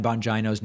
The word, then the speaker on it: Bongino: You disappointed me when you pronounced Dan Bongino's name incorrectly. The I is pronounced as a long E and not an I.